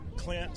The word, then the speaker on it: Clint: Clint, C-L-I-N-T, Herring, H-E-R-R-I-N-G.